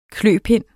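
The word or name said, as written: kløpind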